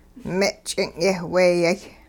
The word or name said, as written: me' ch'ing' yeh weyay